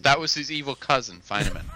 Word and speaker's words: Feynman: Feynman? I pronounce that right? – Mm-hmm. – Right. – Feynman. – Feynman. – Feynman. – Yeah, F-E-Y-N-M-A-N.